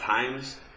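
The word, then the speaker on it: Hyames: Is it pronounced Himes? – H-Y-A-M? Yes. – And I know that in the newspaper. – That was Himes Field. Yeah. – And I know that there was a guy named Judge Himes, and so I'm not very smart, yeah.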